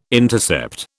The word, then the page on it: intercept: British English